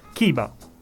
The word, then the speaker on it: Chiba: Chiba.